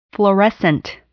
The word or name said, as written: fluorescent